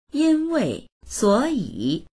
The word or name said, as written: yin1wei4suo3yi3